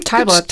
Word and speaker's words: Talbot: Talbot.